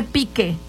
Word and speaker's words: Pike: Ella se llama Krista Pike, se escribe Pique, P-I-K-E.